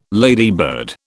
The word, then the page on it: ladybird: British English